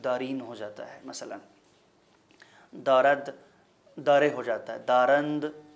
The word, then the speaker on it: دارد: تو میں عرض کر رہا تھا کہ آنجا جو ہے وہ انجا ہو جاتا ہے، مثلاً دارد کی میں نے مثال دی کہ دارد جو ہے وہ دارے ہو جاتا ہے،